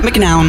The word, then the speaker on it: McNown: Max McNown. Zo spreek je juist. McNown? – McNown.